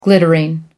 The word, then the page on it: glittering: American English